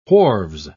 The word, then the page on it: wharves: British English